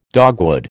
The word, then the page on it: dogwood: American English